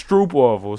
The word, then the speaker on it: Stroopwafel: But how would you say that? It's Stroop waffle.